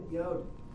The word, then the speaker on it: Yod: You did it, Yod.